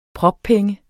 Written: proppenge

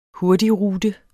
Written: hurtigrute

